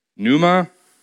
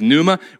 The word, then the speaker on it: pneuma: The word is pneuma. That's kind of an English pronunciation. The word is pneuma.